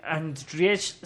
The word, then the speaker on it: Andrzej: Y a fin de cuentas se le hizo a Henry Cavill basada en estas novelas de Andrzej Sapkowski. Andriev, no sé cómo se pronuncia, cabrón. Bueno, escritor ruso es. – Andrzej. – ¿Qué es? – Andrzej es ruso.